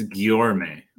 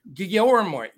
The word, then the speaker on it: Guillorme: Guillermo, how do you say his name? – I think it's Guillorme. – Guillorme.